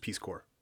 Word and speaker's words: Corps: Just Peace Corps. – Why does it say corps? – Because that's how it's spelled.